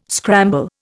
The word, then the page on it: scramble: Indian